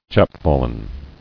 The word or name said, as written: Chapfallen